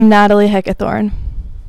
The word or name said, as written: Natalie Heckathorn